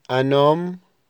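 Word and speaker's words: un homme: انوم انوم ام يعني رجل، الاش لا تنطق،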